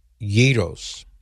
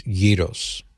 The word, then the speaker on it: gyros: This right here, gyros. That's how I pronounce it. How are you supposed to pronounce it? They said, yes, that's how you pronounce it. It's not gyro. It's not gyro. It's gyros.